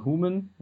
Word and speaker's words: hoomn: Also schreiben tut man uns H O-O-M-N, also einmal H, Doppel O, M, N, ausgesprochen Human, weil es ja abgeleitet ist aus dem englischen Human.